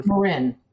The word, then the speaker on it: Marin: I mean, there's clearly something going on outside of that, but at the same time, you know, the is it pronounced, is it Marin, Ireland? Is that how you pronounce her? – Marin. – Marin, sorry.